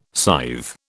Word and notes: scythe: British English

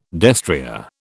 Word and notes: destrier: British English

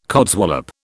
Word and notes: codswallop: British English

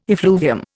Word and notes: effluvium: Indian

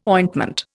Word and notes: ointment: Indian

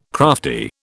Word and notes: crafty: British English

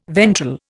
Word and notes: ventral: Indian

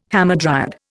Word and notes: hamadryad: Indian

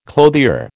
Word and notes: clothier: American English

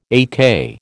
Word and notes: AK: American English

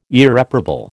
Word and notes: Irreparable: American English

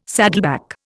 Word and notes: saddleback: Indian

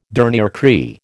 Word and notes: dernier cri: American English

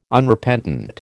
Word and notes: Unrepentant: American English

